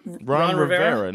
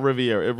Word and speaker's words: Rivera: Is it Rivera? How do you say his name? I can't speak. – Ron Rivera. – Not Riviera.